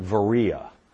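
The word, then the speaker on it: Veria: Berea, we witnessed to a shop owner in Berea, or they call it Varea,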